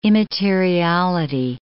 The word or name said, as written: immateriality